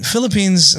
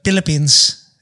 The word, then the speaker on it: Philippines: I mean, Philippines is fine, but Filipinos would be like Philippines. They wouldn't even pronounce the F.